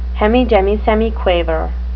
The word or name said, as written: hemidemisemiquaver